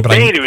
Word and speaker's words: Boehner: Well, Boehner is actually how it's pronounced.